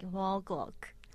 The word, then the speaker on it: ONE OK ROCK: So in Japan, we say like 1 o'clock. – 1 o'clock. So it's like as in like the time 1 o'clock.